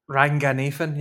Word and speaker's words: Ranganathan: Ranganathan, yeah, Romesh.